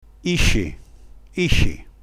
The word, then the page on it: isci: dialetto